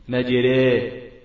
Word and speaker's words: مجراها: عندنا حروف فرعية أو 8 حروف فرعية، أول حرف منهم الهمزة المسهلة بين بين، ثاني حرف منهم الألف الممالة، الألف الممالة أي التي ينطق بها مائلة إلى الياء، ودي عند حفص موجودة في كلمة إيه، وقال اركبوا فيها بسم الله إيه مجراها مجراها مجراها.